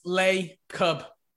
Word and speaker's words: Lacob: Lacob, pronounced Lay-cub. – Okay. All right. – So, yeah, Lacob.